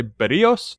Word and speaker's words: Berrios: And now they have Jose, we've been pronouncing this wrong. We were tipped off. – Yes. – Not Berrios, it's Jose Berrios.